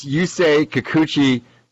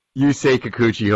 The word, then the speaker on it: Yusei Kikuchi: That's Yusei Kikuchi. Yusei Kikuchi.